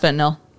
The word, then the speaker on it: fentanyl: He later found out it was fentanyl, which fentanyl. – Yeah, it's pronounced fentanyl. – Oh, is it? – Yeah. – Yeah, it's fentanyl.